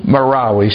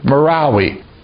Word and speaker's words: Marawi: What's the name of it again? – Marawi. – She knows how to pronounce it. Marawi.